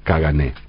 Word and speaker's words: Caganer: Cagané, así que se escribe Caganer, se pronuncia la R igual que en francés, Cagané.